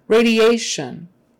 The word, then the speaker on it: radiation: Radiation. On the emphasis on the second to the last syllable before the shun. T-I is S-H. Radiation, radiation. And the IA are two separate vowel sounds.